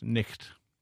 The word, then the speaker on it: knight: And in those days, a word like knight, N-I-G-H-T, would have been pronounced nicht.